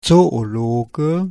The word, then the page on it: Zoologe: /ˌtsoːoˈloːɡə/